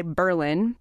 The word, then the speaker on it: Berlin: Massachusetts. Emphasis on the first syllable. And this week on the podcast, we go on a quest to figure out why Berlin has that funny pronunciation.